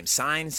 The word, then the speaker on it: Cine: And I'd say, like, we got Dax Hill, Lewis Cine, how do you say his name? Cine, C-I-N-E from Georgia.